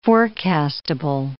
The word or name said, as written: forecastable